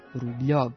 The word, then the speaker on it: Rubljov: Međutim, kada se rusko slovo jo, to jest ono e sa dve tačke, nađe ispred l ili n, čita se ljo, odnosno njo, što u našem primjeru daje izgovor Rubljov i u skladu je sa pravopisnim pravilima transkripcije s ruskog.